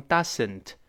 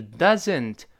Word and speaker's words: doesn't: It doesn't，昨天刚刚讲过，不要读成 doesn't，是 doesn't 发 z 的声音。